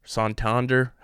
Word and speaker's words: Santander: Santander, however you pronounce his last name. I always pronounce it like the bank.